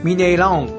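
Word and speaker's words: Mineirão: そういうときはオンって発音する、はい。 – ミネイラオンってなるんですね。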